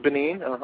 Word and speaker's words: Benin: This is Benin.